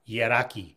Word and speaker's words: yidaki: The one term that's accepted pretty much broadly is the word yidaki or yadaki, and that's spelled Y-I-D-A-K-I, yadaki.